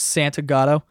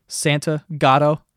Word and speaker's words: Santa Gatto: You know, it's pronounced how you fucking read it. Santa Gatto. People see S-A-N-T and they see a G in there and they go, oh, Santiago, and they're like, oh, he's Spanish.